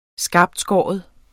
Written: skarptskåren

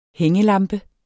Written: hængelampe

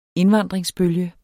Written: indvandringsbølge

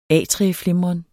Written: atrieflimren

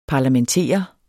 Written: parlamentere

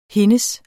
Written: hendes

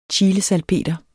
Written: CHILESALPETER

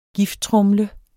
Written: gifttromle

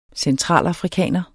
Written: centralafrikaner

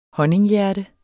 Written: honninghjerte

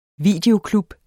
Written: videoklub

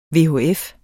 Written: VHF

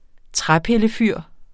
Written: træpillefyr

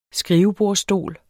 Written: skrivebordsstol

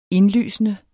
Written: indlysende

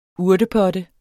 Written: urtepotte

